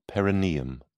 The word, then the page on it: perineum: British English